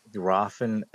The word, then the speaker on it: Rothen: Rothen, it's like the H is silent, and it actually stands for Raven.